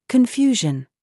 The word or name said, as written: YOU